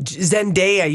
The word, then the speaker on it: Zendaya: I thought it's Daya. – Zendaya. – I said it right. – Yeah, you did say it right, Julia. – I would like you to say that again. – Zendaya, you got it right, Julia.